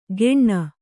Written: ಗೆಣ್ಣ

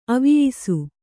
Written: ಅವಿಯಿಸು

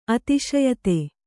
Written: ಅತಿಶಯಿತ

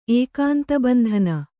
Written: ಏಕಾಂತಬಂಧನ

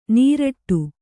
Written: ನೀರಟ್ಟು

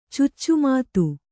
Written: ಚುಚ್ಚುಮಾತು

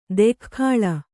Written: ದೊಗ್ಗಳೆ

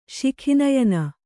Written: ಶಿಖಿನಯನ

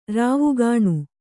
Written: ರಾವುಗಾಣು

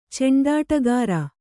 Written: ಚಂಡಾಟಗಾರ

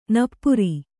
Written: ನಪ್ಪುರಿ

